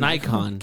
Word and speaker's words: Nikon: Wait, do you mean Nikon? – Is that how you say it? – Nikon. – Get the fuck out of here. – It's not an X, buddy.